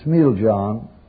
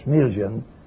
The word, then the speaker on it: Smiljan: In the year 1856, in the small Yugoslavian town of Smiljan, or Smiljan, a Yuka that can pronounce in Yugoslav.